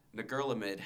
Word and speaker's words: Ngerulmud: The world's smallest capital city, Ngerulmud, has a population of only 400.